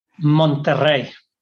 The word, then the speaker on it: Monterrey: Okay, so you grew up in Mexico, and how do you pronounce the name of the city? Tell me how to say it. – Monterrey. – Okay, I thought so. I was close in my mind. – Yeah, you really need to roll the R's on that one.